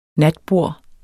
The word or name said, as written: natbord